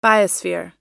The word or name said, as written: biosphere